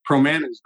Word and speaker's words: Promanis: Promanis Group. Rhymes with banana.